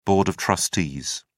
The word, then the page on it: board of trustees: British English